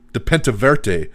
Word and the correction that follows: Pentaverate: Whatever the fuck it's called. Pentaverate.